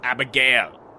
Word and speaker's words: Abigail: Uh, A- Abigail, go down to the train, quick. – It's it's Abigail. The stress is on Gail, but doesn't matter. – Abigail, go to the damn train, please.